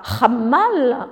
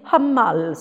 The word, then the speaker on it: hamal: on ne dit pas hammal en, turc, on dit Hamal, c'est un h aspiré, et ça, ils ne l'ont pas par contre.